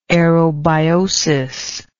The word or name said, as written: aerobiotically